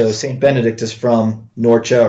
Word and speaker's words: Norcia: Norcia, yes.